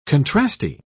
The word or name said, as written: contrasty